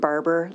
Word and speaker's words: Barbour: Mr. Chairman, members of the committee, my name is Kristen Barber. Last name is spelled B-A-R-B-O-U-R.